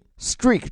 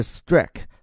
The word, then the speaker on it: streak: 后面说这个 winning streak，注意 streak 这个词，首先它的发音 e a 发的是 e，而不是 strike。